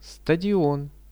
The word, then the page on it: stadion: Russian